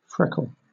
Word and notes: freckle: British English

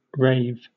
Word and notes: rave: British English

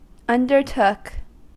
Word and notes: undertook: American English

